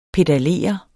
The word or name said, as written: pedalere